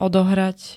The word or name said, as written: odohrať